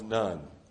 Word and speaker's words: Nun: the son of Nun. Now, Nun is not spelled N-O-N-E. It's spelled N-U-N.